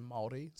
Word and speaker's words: Māori: like, as a resident New Zealander, I will just correct the room. It's pronounced Māori. So you roll the R.